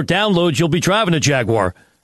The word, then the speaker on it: Jaguar: Chuck, is it Jaguar, like Star Wars, or Jagwire? – Well, I'm going to go with the British enunciation and Jaguar, because it just sounds so cool.